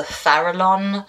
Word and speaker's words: Farallon: It is from 2005, and it is set in what YouTube tells me is pronounced the Farallon Islands.